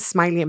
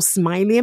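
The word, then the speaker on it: Smilie: So on Instagram, Smiley Empowerment. And again, my name is spelled not with a Y, S-M-I-L-I-E. It is my real name. So Smiley Empowerment.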